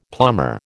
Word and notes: plumber: American English

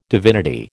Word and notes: divinity: American English

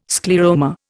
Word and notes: scleroma: Indian

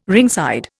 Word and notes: ringside: Indian

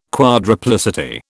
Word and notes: quadruplicity: British English

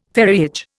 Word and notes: ferriage: Indian

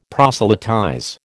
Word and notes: proselytize: American English

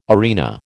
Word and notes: arena: American English

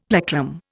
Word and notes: plectrum: Indian

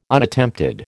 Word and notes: Unattempted: American English

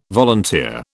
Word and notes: volunteer: British English

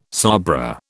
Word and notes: sabra: British English

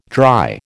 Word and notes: dry: American English

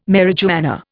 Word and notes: marijuana: Indian

